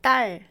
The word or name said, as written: 딸